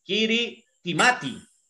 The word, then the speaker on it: Kiritimati: La isla de Kirimi, Kirimita, Kirimi, se escribe Kiritimati.